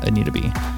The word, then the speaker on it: Anita B: So that is Anita, A-N-I-T-A, the letter B, .org, for those who are wondering how to pronounce it, Anita B.